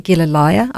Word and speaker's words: Gilleleje: It's not an easy name to pronounce, but it's in English you pronounce it Gilleleje, I believe.